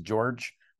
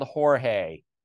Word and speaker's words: Jorge: You know, a number of his of Jorge Posada's teammates would refer to him as George or Georgie. And that, and I remember asking Posada, like, how do you like your name pronounced? And he was so specific, and you could hear that it was important to him. I like being called Jorge.